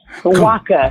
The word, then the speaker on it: Nwaka: Nwaka. Cool. – Thank you. – Silent N. Yes.